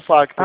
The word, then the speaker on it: Facta: Só falta o Facta em si. Aí você apaga tudo, né? O banco Master e precisa Facta. Aí o C é mudo, tá?